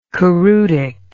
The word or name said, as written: choreutic